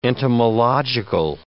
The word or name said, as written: entomological